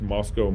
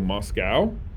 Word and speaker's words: Moscow: Now, the tendency is for everyone to want to pronounce Moscow, Moscow, because of Russia. But the locals bristle at that, so we're very particular on how you pronounce Moscow.